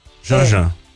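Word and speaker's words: Jeanjean: Fui ver o nome mesmo, Leolia Janjan mesmo, porque o que escreve-se Janjan. – Janjan. – É. Isso. – Leolia Janjan. – É Leolia. – Leolia Janjan, exatamente. Ou seja, a pronúncia está Leolia correta.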